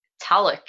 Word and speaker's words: Tulloch: Tuluk? – Talik, actually. – Talik, almost.